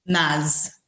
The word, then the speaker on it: Maz: Maz.